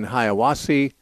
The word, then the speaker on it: Hiawassee: And our first caller today is from Mark in Hiawassee, Georgia.